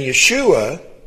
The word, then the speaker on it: Yeshua: And I got a little confused on this, and this is why I wanted to get into it, because Joshua and Yeshua are not the same in Hebrew. Joshua is Yehoshua, where salvation is Yeshua. The long O sound is not in there where it is in Joshua, all right?